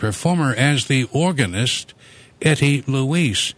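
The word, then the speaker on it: Louiss: the hour, this is the French organist Etty Louise, and these are brand new recordings from the organist Etty Louise.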